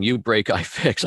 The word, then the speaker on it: UBREAKIFIX: With do you think uh, you break it, I fix? We'll we'll go in, uh, and I keep saying it wrong. You break, I fix. I put in the word it there.